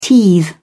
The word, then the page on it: teethe: British English